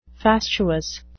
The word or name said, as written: fastuous